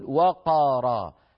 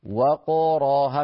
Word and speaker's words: وقارا: أرجو أن تكون الكاميرا قريبة مني لو سمح الأستاذ المخرج، و فنفتح الشفتين هكذا و ثم نأتي بقاف مقلقلة وق ثم نفتح الراء فنقول را. أعيد و ق را و ق را، ولا نقول وقارا، لا نباعد بين الفكين عند نطق القاف الساكنة، فتصير هكذا وقارا، هكذا الصواب طبعا يعني.